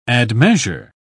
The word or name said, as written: admeasure